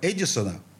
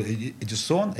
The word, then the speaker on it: Эдисон: Это будет группа «Лампа Эдисона». Ну, тут ударение можно ставить где угодно. Эдисон, Эдисон.